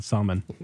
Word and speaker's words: Salman: It's not Solomon, but it's pronounced more like that than salmon. – Solomon? – Yeah, Salman.